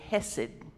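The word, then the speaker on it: chesed: It is the word hesed, but you spell it C-H-E-S-E-D. C-H-E-S-E-D. Hesed.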